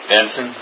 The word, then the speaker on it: Benson: Hey, could you say your last name again? – Benson. – B-E-N-S-O-N? – Yes.